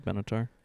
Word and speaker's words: Benatar: But mine is Love is a Battlefield by Pat Benatar. I think I said her name right. – Yeah, it's Pat Benatar.